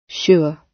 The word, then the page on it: sure: British English